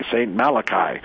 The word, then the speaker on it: Malachy: So as I start looking at this, I came across, it was pointed out to me, the prophecy of Saint Malachi, M-A-L-A-C-H-Y. If you're Irish, you pronounce the Y like it sounds. It's a Y, Saint Malachi.